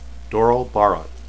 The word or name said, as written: doral